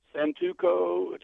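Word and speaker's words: Santoku: A 7-inch, it's pronounced Santuco.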